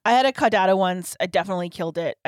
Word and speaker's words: Caudata: And then there's, how do you say it? I always want to say like the dog. – Caudata.